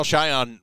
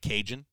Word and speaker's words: Cajan: This has been my joke ever since uh, I realized that his name is spelled C A J A N, so it looks like Cajun, and it's uh, Cajan.